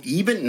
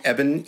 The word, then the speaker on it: Eban: Oh, Eben? No. Why wouldn't it be pronounced Eben? It should have been. Anyway, it's Evan, E-B-A-N, Schletter, S-C-H-L-E-T-T-E-R.